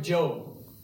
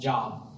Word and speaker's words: Job: So let me illustrate it with a story in the Bible, the story of Job. You might have read J-O-B and thought like Kanye West, Job. Who puts Job in the Bible? But you pronounce it Job. I don't know why. We just do.